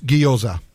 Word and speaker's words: gyoza: But we now know it's gyoza, which are like a Japanese fried dumpling.